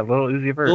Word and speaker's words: Lil Uzi Vert: Lil, Lil Uzi Vert. – Uh, yeah, you got it right. So Lil did, Uzi I say it right? Yeah, Lil Uzi Vert.